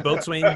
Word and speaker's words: Boatswain: Boatswain. How do you say Boats. Boats. That's boats. – Boatswain. – Because it definitely sounded like you said Butzwain.